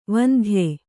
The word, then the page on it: ವಂದ್ಯ: vandya